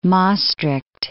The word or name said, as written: Maestricht